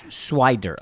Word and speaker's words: Swider: Is Cole Swinder getting early minutes? – Cole Swinder. – And then I look at the... did I say his name wrong? – He said Swinder. – Swider.